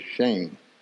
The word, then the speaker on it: Shem: But it's spelled S-H-E-M, but the pronunciation of it is Shame.